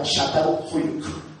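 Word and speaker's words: Çatalhöyük: Y se pronuncia Catán Huyuk.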